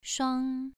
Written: shuang2

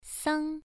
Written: sang1

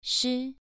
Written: shi2

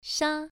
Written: sha1

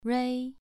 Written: rui2

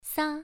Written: sa1

